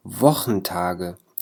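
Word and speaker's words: Wochentage: اليوم رح نتعرف على أيام الأسبوع ودرسنا بعنوان دي وخنتاج. دي وخنتاج، التساء والها هنا تلفظ مثل حرف الخاء في اللغة العربية، يعني هي الوخ الأسبوع.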